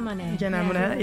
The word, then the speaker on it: Monáe: Did you pronounce it like this? – Janelle Monáe. – Yeah.